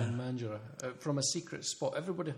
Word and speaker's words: Mandurah: Come from back from Mandurah. Is that how you're pronouncing that at the moment? – Right, Mandurah. Sunny Mandurah.